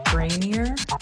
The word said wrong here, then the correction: Granary: May have butchered. It's actually Granary 12. – Granary.